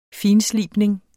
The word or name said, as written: finslibning